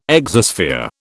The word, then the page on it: exosphere: British English